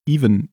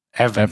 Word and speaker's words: Evan: Die, die Mutter, die da heißt, nämlich Andrea Treburn, die Mutter von, äh, wie wird er ausgesprochen? Even, nee, doch, Evan, oder? – Evan. Evan, genau, Evan.